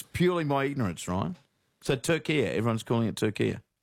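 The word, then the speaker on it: Türkiye: But in yesterday's Socceroos update, you kept saying Turkey and not – Turkia.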